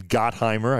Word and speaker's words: Gottheimer: Oh, we also spoke by phone to Congressman Gottenheimer. Congressman Gottheimer, I should say it properly.